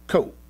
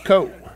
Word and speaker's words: ko: It's just pronounced ko. In case you wanted to know the Hebrew word for vomit. It's just ko, right?